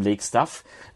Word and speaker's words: Lake Staff: Also eigentlich war Lake Staff schon richtig ausgesprochen, aber wir sagen trotzdem Lake Staff, also mit S-T-A-Doppel-F.